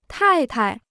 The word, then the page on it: 太太: tài tai